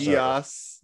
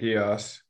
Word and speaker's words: Geass: I don't even know how to pronounce Geass. – That. Geass.